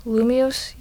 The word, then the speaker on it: Lumiose: Lumiose City? – Lumiose, yeah.